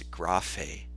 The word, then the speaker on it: graphē: The way you pronounce it in the Greek is graphē, but you might be familiar with English words like geography or topography or biography.